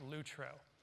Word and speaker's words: lutro: That word in the original is pronounced lutro.